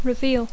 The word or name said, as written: reveal